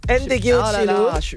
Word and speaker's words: Ndegeocello: Ndegeocello.